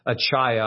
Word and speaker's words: Achaia: I've pronounced it wrong for years. I looked it up finally today. It should be pronounced Achaia. I've always called it Achaia.